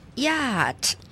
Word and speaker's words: yacht: Yacht, 注意到哦，它的拼法是 Y-A-C-H-T, 但是念 yacht, 所以显然是中间 C-H 没有发音 Y-A-C-H-T, 但是要念成 yacht, 在英文里面有很多类似的情形，某些字母是不发音的，比如说我们讲数目字 8 叫做 8, E-I-G-H-T, 但是那个 G-H 就没有发音。